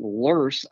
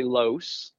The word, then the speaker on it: loess: You know, when I first got here, I'm like, how in the world are you getting lurse out of a word that's spelled L-O-E-S-S? And someone about 2 years ago, it took forever to find this out, that it's a derivation from a German word that more closely resembles that pronunciation, hence the R sound in there.